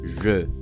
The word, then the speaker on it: je: This sound is also present in the word I in French, which is pronounced je. E.